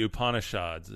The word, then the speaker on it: Upanishads: And then the next one, this is the Upanishads, is how you say this.